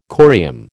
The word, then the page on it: corium: American English